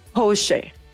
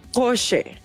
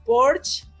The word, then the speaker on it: Porsche: Y solo como un dato cultural, déjenme les cuento que es alemana y su correcta pronunciación es, escuchen, Porsche. Ándele. – ¿No? A ver, repítelo, ¿cómo? – Porsche.